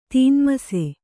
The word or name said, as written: ತೀನ್ಮಸೆ